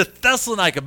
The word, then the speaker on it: Thessalonica: I got that one right, I know, because I've been practicing that one a lot. They came to Thessalonica. By the way, very interesting thing about Thessalonica.